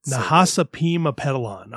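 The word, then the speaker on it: Nahasapeemapetilon: Nahasapeema Petalon. I always put an N in there. It's an M.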